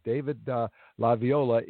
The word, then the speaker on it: LaViola: David Devo, and I've got to say this right because it's somewhat Italian, David Di Viola, which is the name of a music instrument, but not one that he plays. David LaViola is the lead vocalist, and he's the rhythm guitarist for one of the most fun bands playing in L.A.